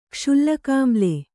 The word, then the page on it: ಕ್ಷುಲ್ಲಕಾಮ್ಲೆ: kṣullakāmle